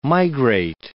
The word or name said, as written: migrate